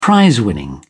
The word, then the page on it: prizewinning: English; British English